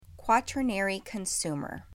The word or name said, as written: Quaternary Consumer